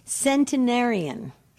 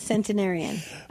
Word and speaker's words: centenarian: So the proper pronunciation of a person who's 100 years old or more is centenarian. – Okay. – Centenarian.